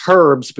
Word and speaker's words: herbs: Well, you know, you actually pronounce herbs because there's an H in it.